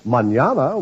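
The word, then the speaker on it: mañana: It's the word manana, spelled M-A-N-A-N-A, and pronounced manana. In Spanish, that means tomorrow.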